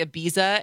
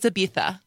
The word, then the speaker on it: Ibiza: So when you say Ibiza and then someone corrects you and goes, it's Ibiza.